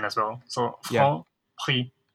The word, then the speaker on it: Franprix: I mean, with most French words, you don't pronounce the last letter. – Mm-hmm. – So like some of the last letters like X, for example. – Yeah. – So actually Franprix is the way to pronounce it.